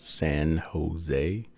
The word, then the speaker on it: San Jose: In the Dark, 101.5 UMFM, Freddie King, a track called San Jose. That's spelled SANHO-ZAY. I'll say the Canadian way, ZOY.